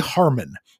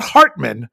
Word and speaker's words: Harmon: Most of them come from Twitter, but this one came from Jeffrey Harmon, not Hartman, Harmon. I had to make sure I say that right.